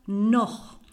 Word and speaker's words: noch: La partícula noch. Recordar que noch, la C, eh, se escribe con CH porque en alemán CH se pronuncia así, como ich, noch, ich, noch, ¿vale?